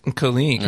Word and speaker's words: Kalik: You're saying Kiliak. – I was right. – Saying Kiliak. – Right. – And so this guy's like, hey, man, it's pronounced Kalik, yeah. Not, you know.